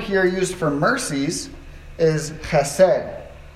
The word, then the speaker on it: chesed: Everyone say chesed with the throat. Chesed. Okay, no one's going to say it. On the count of three. One, two, three. Chesed. Right. It's a Hebrew word. You know you're saying it right in Hebrew if you're like coughing something up out of your lungs.